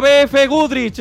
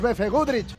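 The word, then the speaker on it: BFGoodrich: Era BF Udrich. Te vas a casa sin un duro y sin aplauso ni nada. – Pues por lo menos me podías regalar unos neumáticos BF Udrich. ¡Ahí va! ¡Ahora lo he dicho bien!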